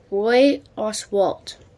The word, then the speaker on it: Roy Oswalt: later broken by Roy Oswalt.